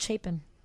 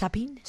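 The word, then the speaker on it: Chapín: Chapin, but it's spelled Chapin. – It is. – But it's pronounced Chapin? – Chapin. It's a Guatemalan word.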